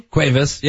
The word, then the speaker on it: Cuevas: Cuevas, yeah.